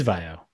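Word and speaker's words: Vaio: I believe it's Vio. – It is Vio. – Yeah. I'm pretty sure it's Vio.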